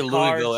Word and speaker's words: Louisville: However, if you say Louisville, everybody knows you're a faker. You got to say Louisville. – Make sure you don't really enunciate anything. – Just Louisville.